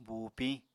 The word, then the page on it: buupiñ: Apatani